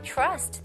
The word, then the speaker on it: trust: I trust this website. Trust, 신뢰하다. Trust, CH 발음, ch, trust.